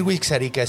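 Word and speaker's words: Wixarika: Yo ya les voy a decir Wixarica siempre, porque eh, no es un nombre eh, huichol.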